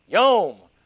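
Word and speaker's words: Yom (יום): It rhymes with home. Yom, okay?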